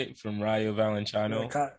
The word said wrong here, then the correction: Rayo Vallecano: Oof, Rayo Vallecano.